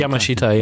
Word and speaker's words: Yamashita: So the people that were making it, even the really high end folk, guys like um, I'm going to try it, Tatsuro Yamashita? Yamashita. Yamashita. yeah, yeah. They don't really pronounce the A's as clearly as we do, though.